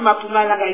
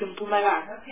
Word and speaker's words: Mpumalanga: he pronounces Mpumalanga correctly, because that is the word we say. Not Mpumalanga, it's Mpumalanga. That's my problem.